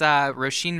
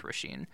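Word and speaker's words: Róisín: So this is Roisin Murphy. Oh, and by the way, if you're listening and you're figuring out how to Google it, her name is spelled O-R-I-S-I-N. – Yeah. – That's Irish Roisin.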